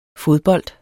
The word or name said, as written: fodbold